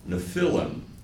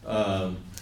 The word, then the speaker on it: Nephilim: But the children were called Nephilim, depends on how you put the pronunciation there. Sorry. Nephilim.